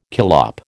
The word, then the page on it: collop: American English